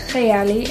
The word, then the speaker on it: Kayali: In Arabic, you actually pronounce it Kayali. It means my imagination.